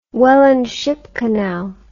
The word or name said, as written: Welland Ship Canal